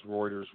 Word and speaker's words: Reuters: Once again, y'all, that's Reuters. I figured out how to say it correctly.